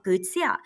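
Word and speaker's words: kwv txhiaj: So in the Hmong culture, our folk music, it's more so of, so I'm going to pronounce it in Hmong, it's called guzia.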